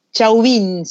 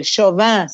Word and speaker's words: Chauvin: Chauvin se escribe, Chauvin sería en realidad, pero es Chauvin el viernes 24 de junio.